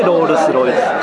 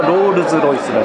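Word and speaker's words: Rolls-Royce: ロールス・ロイス、で日本でロールス・ロイスって言うけど実際の発音はロールズ・ロイスらしいです、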